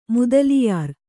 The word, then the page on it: ಮೊದಲಿಯಾರ್: modaliyār